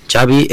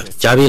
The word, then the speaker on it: Xavi: Yeah, Xavi like chocolate. – Yes, okay, thank you very much. – Xavi like chocolate, Xavi chocolate, muy gráfico. También han sido muy gráficos en la federación explicando que Xavi fue su tercera opción, que antes iban Guardiola y Slot, pero que a la tercera fue la vencida.